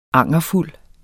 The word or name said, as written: angerfuld